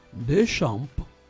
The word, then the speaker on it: Deschamps: Deschamps.